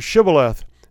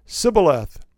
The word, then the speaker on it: shibboleth: And so the Ephraim soldiers would come to the crossing, and the Gilead guys that were there would say, pronounce the word shibboleth. And they would say, sibboleth. And they were immediately identified as the enemy.